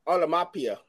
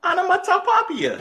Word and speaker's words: onomatopoeia: She said rhymes with Mamma Mia. – Oh, onomatopoeia.